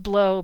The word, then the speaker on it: Blot: Probably it's pronounced blow, but in my mind, it's B-L-O-T. And as a kid, I always pronounced it as Hippolyte Blot, which just sounded like a great name to me as a kid.